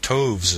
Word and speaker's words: toves: The I in Slithy is long, as in writhe, and Toves is pronounced so as to rhyme with groves.